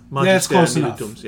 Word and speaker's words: Magister Militum: Oh, you're going to have to help me with the pronunciation. Magister Militums. – Yeah. – Yes. Magister Militums.